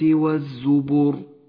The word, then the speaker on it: والزبر: والزبر لونت اللام في كلمة والزبر باللون الرمادي لأنها لا تلفظ فهي لام شمسية تكتب ولا تلفظ